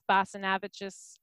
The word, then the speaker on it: Basanavicius: It's just F Basanavicius at, or no, it's just at F Basanavicius.